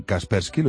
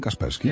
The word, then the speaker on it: Kaspersky: La empresa rusa de seguridad informática, a ver si lo digo bien, Kaspersky, lo he dicho bien, Kaspersky. – Eso es. Fantástico.